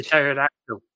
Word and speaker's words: pterodactyl: E for pterodactyl. – Is it? – Yes. – Is it actually? Okay. – Pterodactyl is spelt with a P at the beginning. It's a silent Why does it need to be there then?